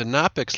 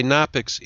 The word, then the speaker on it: Knoppix: By the way, Nopix is spelled K-N-O-P-P-I-X.